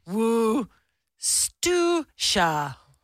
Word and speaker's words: Worcestershire: How is Worcestershire actually pronounced? It's pronounced Worcestershire.